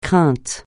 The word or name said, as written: crainte